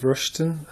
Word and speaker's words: Rushton: Sure, my name's Steve Rushton, full name Stephen, S-T-E-P-H, middle name Peter, P-E-T-E-R, surname Rushton, R-U-S-H-T-O-N.